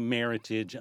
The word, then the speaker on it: Meritage: But it's supposed to be Meritage, American heritage.